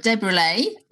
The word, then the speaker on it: Desbruslais: So today I'm really pleased to have Robert Desbruslais on the podcast today. Hello. – Hiya. – Did I pronounce that right? – Pretty much, yeah. It's pretty good attempt actually.